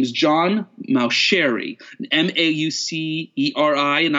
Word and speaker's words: Mauceri: John Mauceri, and now I say it right, the French, the Italian way of saying it.